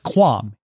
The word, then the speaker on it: QAM: Wi-Fi 7 will also increase capacity and reduce latency thanks to increased quadrature amplitude modulation, which I am assured is pronounced QAM, even though there's absolutely no U in QAM.